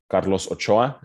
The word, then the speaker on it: Carlos Ochoa: So just for the audience, my full name is Carlos Ochoa, and that is if you want to practice your Spanish pronunciation.